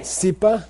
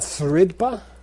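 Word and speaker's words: srid pa: Say yepe, nambar, micholte, mepe, sipa, dilemin. Why isn't that sridpa? That subjoined letter is silent.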